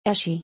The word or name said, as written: Eshe